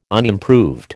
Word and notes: unimproved: American English

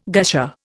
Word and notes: gusher: Indian

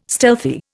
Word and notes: stealthy: Indian

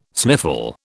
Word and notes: sniffle: British English

